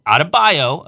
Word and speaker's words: Adebayo: Adebayo, again, if you're listening, that's how you pronounce that name.